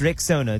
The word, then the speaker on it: Rexona: Stepping forward with a bold new name, it's pronounced Rexona.